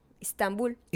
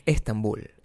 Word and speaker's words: Estambul: Istambul. – Istambul es eh, Estambul, Estambul, en Turquía.